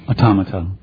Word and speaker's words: automata: the automata, how do you say that? – Automata. – Yeah, thank you.